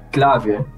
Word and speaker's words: Clave: Sergio said on last time on here, he actually said my name, Clave, which is actually how you pronounce it in like here at Aruba, that's how you actually pronounce it, but more simple for everyone, just say it Clave.